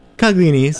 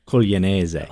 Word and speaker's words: Coglionese: How would you say it? – Coglionese. – All right, cool. – Well, unless I was speaking to another Italian, then yes. I'd say Coglionese.